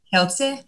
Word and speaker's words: Kielce: It begins with K. It's in Poland. – Kielce? – Yeah. You're better at that than I am.